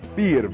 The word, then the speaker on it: vier: Vier, V-I-E-R. They already, this shows you directly where they took our numbers from. The German language for number four is the same pronunciation. They pronounce it the same exact way.